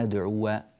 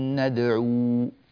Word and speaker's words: ندعو: فالخلاصة أيها الأخ الحبيب، هكذا نقرأ: لن ندعو، جميل، لن ندعو من دونه إلها، لن ندعو من دونه إلها، وإذا وقفنا لن ندعو، هكذا، فالألف لا تنطق بتاتا.